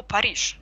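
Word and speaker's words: Paris: Или то же самое слово «Париж». Слово «Париж» вообще нигде нет. Во французском произносится «Пари», пишется «Парис».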